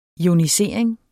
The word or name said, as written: ionisering